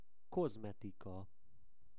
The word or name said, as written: kozmetika